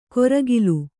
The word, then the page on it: ಕೊರಗಿಲು: koragilu